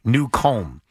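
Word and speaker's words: Newcomb: It's spelled new, N-E-W, and then comb, C-O-M-B, new comb.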